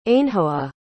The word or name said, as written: ainhoa